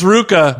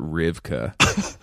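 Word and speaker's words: RVCA: It's Ruka. – Don't act like you haven't lived in Southern California long enough to know how to pronounce that. – I honestly didn't know. It looks like a V. It is a V.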